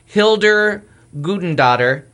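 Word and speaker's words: Hildur Guðnadóttir: Hildur Guttadottir.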